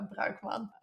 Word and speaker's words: Bruikman: Um, it's my first name, first name, last name, so Hester, and then again the name you so wonderfully pronounced, uh, Bruikman.